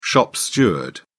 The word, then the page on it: shop steward: British English